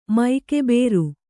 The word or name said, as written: ಮೈಕೆಬೇರು